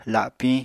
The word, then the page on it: lapiñ: apatani